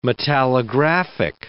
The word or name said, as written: metallographic